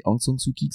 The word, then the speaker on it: Aung San Suu Kyi: Alors attends, c'est Aung San Suu Kyi que ça se prononce.